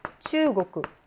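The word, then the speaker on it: 츄고쿠 (中国): 보통 한국 할땐 코쿠로 읽히잖아, 칸코쿠. 근데 츄고쿠만 고쿠라고 읽어요. 츄고쿠. 칸코쿠는 칸코쿠예요. 칸코쿠, 츄고쿠. 츄고쿠입니다. 그래서 이 코쿠와 이 코쿠를 구별을 하셔야 되겠죠.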